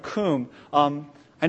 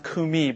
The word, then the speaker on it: kum: And what about the second word, kum? I know it looks like it's pronounced kumi, but it's pronounced kum.